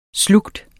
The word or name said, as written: slugt